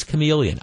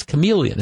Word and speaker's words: chameleon: I know it's chameleon. I know the H is silent, but I want to say it's Shyamalan.